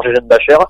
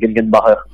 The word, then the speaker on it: Gegenbacher: Alors, suivant les prononciations, euh, la prononciation la plus usuelle que lui-même utilise, c'est Nathan Gegenbacher. – Gegenbacher. S'il fallait le prononcer, euh, s'il fallait le prononcer à l'allemand euh, bien comme il faut, ce serait Gegenbacher.